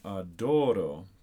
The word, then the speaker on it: Adoro: One note about that first phrase is the accent is actually on adoro, so you might feel the need to place the accent on the first syllable, but it's actually on the second syllable.